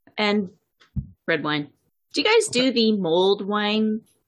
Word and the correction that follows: mulled wine: It's pronounced mulled wine. Mulled wine, not mold wine.